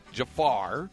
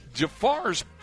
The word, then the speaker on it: Jafar: Ali Jafar and Yusef Jafar, not spelled the same as the Jafar from Aladdin fame. This is two R's, two A's in the word Jafar, you know, before the F.